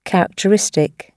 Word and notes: characteristic: British English

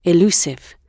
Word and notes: elusive: British English

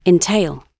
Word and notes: entail: British English

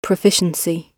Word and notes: proficiency: British English

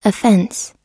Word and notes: offense: American English

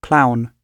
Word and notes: clown: British English

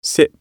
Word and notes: sip: British English

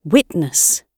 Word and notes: witness: British English

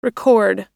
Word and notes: record: American English

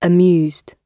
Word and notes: amused: British English

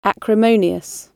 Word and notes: acrimonious: British English

